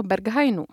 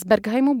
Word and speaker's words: Berghain: Respektive z berlínského Berghainu. Z Berghainu bude galerie. V září tam začne výstava. Já jsem si až doposavat myslela a dosud to vyslovuju špatně. Říkám tomu klubu Berghain, ačkoliv je to Berghain.